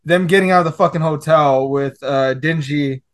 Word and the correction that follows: Denji: Am I pronouncing it right? Dingy? – Denji, Dingy?